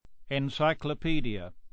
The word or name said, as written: encycopedia